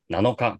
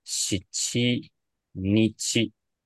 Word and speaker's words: 七日: 然后，那七跟九的话呢，不要念不啊、呃，不需要念成这个 nanoka 跟 kokonoka 啊，但是它念什么呢？这边的七跟九，你要记得念成 shichi nichi，还有 ku nichi，也就是七要念成 shichi，九要念成 ku 的意思。